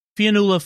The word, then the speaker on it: Fionnula: You before I do that, I do want to point I forgot to mention Fiona, Flanagan. I mispronounced her name. Fionnula Flanagan plays the widow, who also in, uh, will previously had played Data's mom, uh, the wife.